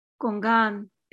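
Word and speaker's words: gong'an: Gongan is the original Chinese word that is pronounced koan in Japanese.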